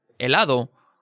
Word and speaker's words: helado: The thing about la H is that it's never pronounced. It's totally silent. So even if a word begins with an H like I, helado, or hierbabuena, there's no trace of any H sound.